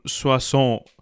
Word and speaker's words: Soissons: Soissons herum, wenn ich das richtig ausgesprochen habe.